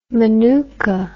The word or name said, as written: manuka